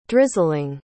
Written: drizzling

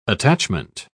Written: attachment

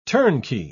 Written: turnkey

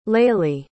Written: laylie

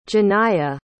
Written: Janiya

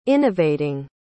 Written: innovating

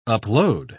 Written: upload

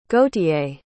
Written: gauthier

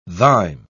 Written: thine

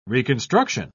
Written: reconstruction

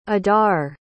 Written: adar

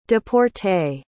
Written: deporter

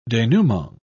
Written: denouement

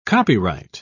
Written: copyright

